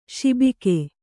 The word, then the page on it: ಸೊಬಗ: Kannada